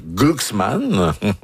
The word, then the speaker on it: Glucksmann: Et Ségolène Royal a lancé dimanche une première salve contre le candidat Raphaël Glucksmann.